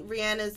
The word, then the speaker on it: Rihanna: You get to see Rihanna's thought process. Yes, yep. Now I'm saying Rihanna because that's how you say it. And now that I know it, I can't unknow it.